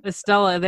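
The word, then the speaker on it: Estela: Is this Estrella or something? It's very Spanish. – Estella? – Estella, there we go.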